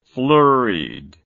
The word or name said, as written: flurriedly